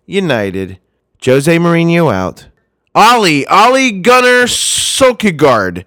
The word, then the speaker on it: Solskjaer: But you are close without saying Kagard on the end of it. – There's no Kagard there. It's Solskjaer. Well, I'm going to call him the soldier.